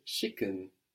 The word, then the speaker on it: schicken: You will also very commonly hear schicken, schicken, which is spelled like chicken, but with an S at the beginning, no?